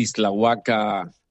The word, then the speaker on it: Ixtlahuaca: Es el rector de la universidad, no sé si voy a pronunciar bien el nombre, él me va a corregir, Universidad de Ixtlahuaca, en México. Profesor Margarito, buenos días. – Qué bien lo pronuncia don Jorge Cura, es un placer saludarle. Efectivamente,